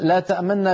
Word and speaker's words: تأمننا: ما لك لا تأمننا على يوسف تنطق نونين والنون الأولى تسرع عندها قليلا مع خفض في الصوت، هذا هو الروم. الاختلاس أن تنطق نونا واحدة مشددة وتضم الشفتين مع الغنة المنطوطة هكذا. ما لك لا تأمن على يوسف، هكذا الروم والإشمام في هذه الكلمة.